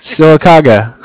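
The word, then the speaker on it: Sylacauga: I'm not going to say this right. Silicaga? Is that right? – That's good. You did good. – Silicaga.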